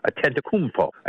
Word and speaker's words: Antetokounmpo: Antetokounmpo, I think, is the best way.